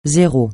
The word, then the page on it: zéro: francais